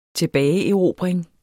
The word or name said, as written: tilbageerobring